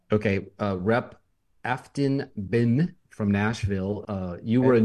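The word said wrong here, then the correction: Behn: How do you say it? Afton Bin? – Afton Bain, I believe, is how the last name's pronounced.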